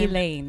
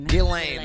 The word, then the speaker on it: Ghislaine: How do you pronounce her first name? – Ghislaine. – Ghislaine. There you go.